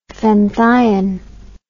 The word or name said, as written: fenthion